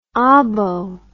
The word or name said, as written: obbo